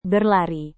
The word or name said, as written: berlari